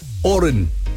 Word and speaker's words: Horan: yes, he prefers the silent H. Oran. H-O-R-A-N.